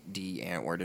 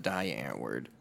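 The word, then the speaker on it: Die Antwoord: But that's where I learned that D-Antword is pronounced D-Antword and not Die-Antword.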